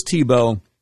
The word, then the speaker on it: Thibault: Mr. Director, who is Timothy Tebow? – Now, he's not talking about the former football quarterback. This Tebow is spelled T-H-I-B-A-U-L-T, just so you know.